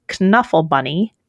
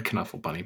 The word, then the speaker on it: Knuffle: And Trixie is insisting on pronouncing the K, which got me to change my pronunciation of Knuffle Bunny.